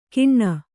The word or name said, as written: ಕಿಣ್ಣ